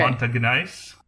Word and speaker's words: Montagnais: and this is a Frenchified word, so I'm going to say it wrong, uh, okay. Montagnais. Okay. M O N T A G N A I S.